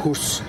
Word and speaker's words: hus: Hus. – Because I was living in Sweden, and the word for house in Swedish is hus. – Hus. And you don't say the H.